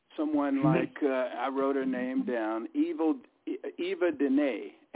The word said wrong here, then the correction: Yvonne Dene: Am I pronouncing her name right? Eva Dene. Is that her? – Yvonne Dene. It took me a whole show to learn how to pronounce her name.